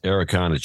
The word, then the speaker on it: Araucana: The blue color is created by, I don't know what this means, Okeanin, which is Araucana chicken. I don't know how they're spelling it there, but that's how it's pronounced.